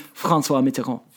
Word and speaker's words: François Mitterrand: It is François Mitterrand.